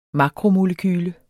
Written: makromolekyle

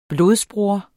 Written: blodbroder

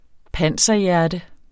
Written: panserhjerte

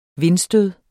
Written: vindstød

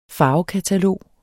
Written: farvekatalog

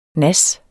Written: nas

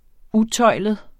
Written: utøjlet

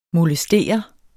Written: molestere

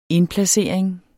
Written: indplacering

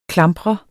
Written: klampre

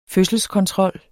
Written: fødselskontrol

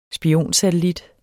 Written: spionsatellit

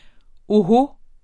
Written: oho

